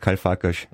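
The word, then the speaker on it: Karl Farkas: Dabei heißt es doch Karl-Farkas. – Ja. – Also das war falsch.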